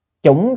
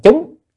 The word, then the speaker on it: chủng: Chủng chư thành thiện căn, chữ chủng, nếu mà theo từ điển á thì cái chữ này á, chủng là mặt trồng, còn có khi đọc là chúng, chúng chư thiện căn cũng được, cũng là trồng, nhưng mà ở Việt Nam các vị chư tổ thường đọc chủng luôn là trồng luôn rồi.